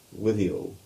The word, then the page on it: Withiel: English